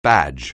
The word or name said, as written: badge